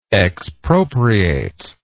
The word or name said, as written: expropriationist